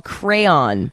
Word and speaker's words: Craiyon: It's called Crayon, but it's spelled C-R-A-I. Y-O-N.